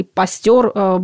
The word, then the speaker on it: Пастер: Да и в 19 веке, по сути, тоже Луи Пастер или Пастер, более правильно его произносить будет так, скорее были исключениями.